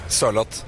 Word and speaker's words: Sørloth: How are you supposed to say Sørloth? Sørloth? How do you say that? – A bit slower. Sørloth.